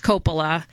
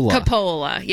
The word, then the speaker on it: Coppola: And I think it's pretty well documented that it's Coppola and not... How did she say it? – Coppola, yeah.